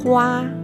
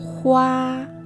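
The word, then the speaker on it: hua (花): Entonces, la primera palabra es flor y en chino se dice hua, hua, hua y ramo de flores, hua shu, hua shu, hua ya sabemos significa flor, y shu en este lugar quiere decir ramo.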